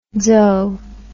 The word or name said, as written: dzo